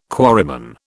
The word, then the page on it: quarryman: British English